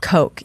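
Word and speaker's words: Koch: Coke, it's K-O-C-H? – Yes. – Indeed. – And it's pronounced Coke. – Coke, yeah.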